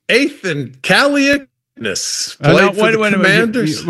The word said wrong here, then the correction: Kaliakmanis: You dropped out in the last. Pronounce Kaliakmanis. Kaliakmanis. – Kaliakmanis. I think.